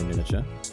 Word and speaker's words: miniature: We get an extra syllable when you say miniature. – Miniature, yeah. I don't usually say that. – You say the second I.